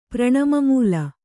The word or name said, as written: ಪ್ರಣಮಮೂಲ